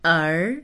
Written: er2

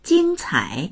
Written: jing1cai3

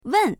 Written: wen4

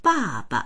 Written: ba4ba

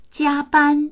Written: jia1ban1

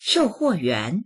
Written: shou4huo4yuan2

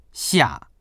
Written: xia4

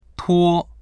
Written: tuo1

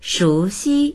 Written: shu2xi1

